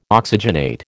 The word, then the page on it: oxygenate: American English